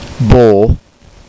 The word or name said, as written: bó